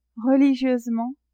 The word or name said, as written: religieusement